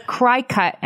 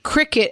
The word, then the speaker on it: Cricut: And I want to defend myself by saying, as a spelling bee champion who is very aware of the rules of phonics, cry cut is how you pronounce that collection of letters. – Wait, I'm going to push back on that one. It's spelled cricket and it has an antenna on top of the C. – It's spelled C-R-I-C-U-T.